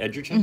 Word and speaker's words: Edgerton: Is that how you say it? Edgerton? – Mm-hmm.